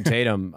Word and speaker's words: Tatum: Jason Tatum. Wait, did I just say his name right? – Yeah. – Jesus Christ. Jason Tatum.